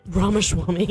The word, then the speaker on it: Ramaswamy: I don't know which one of those two want my votes, Acton or Ramaswamy, but however you say his name. – Ramaswamy. – There you go. Whoever's going to save Burger Hut might get my vote. – I think it's Ramaswamy.